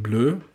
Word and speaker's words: bleu: Naja, das Q und das U und dann kommt das E und das U und das spricht man ja im Französischen oft äh, Ö, wie in Bleu, also ist schon irgendwie.